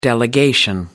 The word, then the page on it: delegation: American English